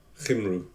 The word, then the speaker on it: Cymru: how do you pronounce the Wales country name in Welsh? Is it Cymru? – Cymru.